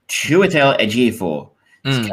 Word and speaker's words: Chiwetel Ejiofor: Chiwetel Ejiofor's character Solomon.